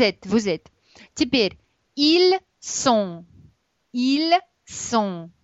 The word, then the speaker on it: sont: Теперь смотрите, T не читается. ON – это носовой звук, поэтому в нос sont.